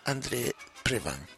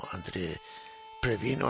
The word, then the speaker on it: André Previn: Se le conoció ya en los Estados Unidos como André Previn o André Previn. Pensaron que le daban una pronunciación francesa al nombre.